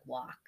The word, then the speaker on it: Wark: A Suffolk District Attorney spokesman, Jake Wark, I'm guessing is how you say that? – Wark? I would say it that way, too. – He probably says it like walk.